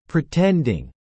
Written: pretending

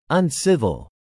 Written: uncivil

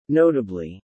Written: notably

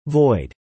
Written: void

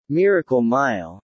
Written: Miracle Mile

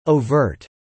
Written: overt

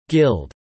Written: guild

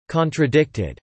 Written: contradicted

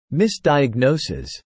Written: misdiagnoses

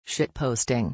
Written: shitposting

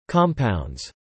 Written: compounds

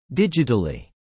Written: digitally

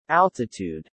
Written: altitude

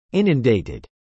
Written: inundated

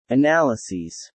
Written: analyses

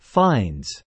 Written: finds

